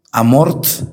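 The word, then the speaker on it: Amorth: El Padre Amor, Amort, se escribe Amort con T al final, fue un exorcista del Vaticano.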